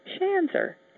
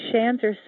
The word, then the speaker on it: Shanzer: My last name is Shanzer. Lucky for me, Shanzer sort of rhymes with dancer, so the answer is Shanzer, a very fine dancer.